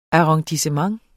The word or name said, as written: arrondissement